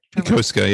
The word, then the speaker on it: Yokosuka: Yokosuka, yeah.